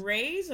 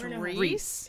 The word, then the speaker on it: Riis: Is it Rays or Rise? I think I it's Reese. It's Reese. Oh my God.